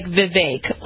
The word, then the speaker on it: Vivek: His name is Vivek Ramaswamy. I'm suggesting... – You want to say it like Vivek, like cake. – Vivek? – Vivek, okay. Like cake, yep.